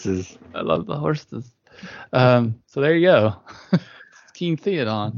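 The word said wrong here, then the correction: Théoden: This is not what I was. – Sorry, Theodon. – How do you spell that? – It's Theoden.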